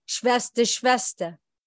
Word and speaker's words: Shvester: Shvester.